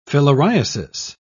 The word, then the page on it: filariasis: British English